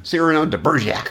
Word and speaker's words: Cyrano de Bergerac: Cyrano. Cyrano de Bergerac. – Emphasis on the first syllable.